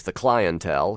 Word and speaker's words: The Clientele: That is The Clientele, British band that almost inevitably is pronounced as The Clientele here in the States, recorded live on Soundcheck on March 25th of this year.